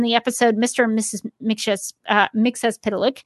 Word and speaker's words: Mxyzptlk: On the CW Supergirl, they pronounce it as Mxyzptlk. Two actors have played Mxyzptlk.